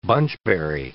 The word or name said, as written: bunchberry